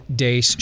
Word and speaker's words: Deace: Last name is spelled D-E-A-C-E. You can also like us on Facebook, follow us on Twitter, at Steve Dace Show.